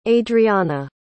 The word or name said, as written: adreana